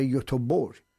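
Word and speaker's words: Göteborg: گی که آخر مثل شهر یوتوبور، ما خب ما ایرانی ها با همدیگه که صحبت میکنیم گیم رفته بودم یوتوبوری،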